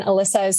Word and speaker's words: Elissa: And then you can find me on Instagram at voicebodyalyssa, and Alyssa is spelled E-L-I-S-S-A.